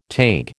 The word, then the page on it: Taig: American English